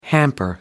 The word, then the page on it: hamper: American English